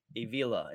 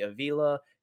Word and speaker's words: Avila: Avila is how I think it's pronounced.